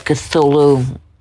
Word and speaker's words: Cthulhu: Its name was given to the Lovecraft-inspired universe where it and its fellow entities existed, the Cthulhu mythos.